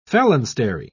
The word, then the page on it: phalanstery: British English